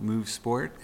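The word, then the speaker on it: MUUV Sport: Again, our company is called Move Sport, and that is spelled a little different. So it's M-U-U-V Sport. So again, M-U-U-V Sport.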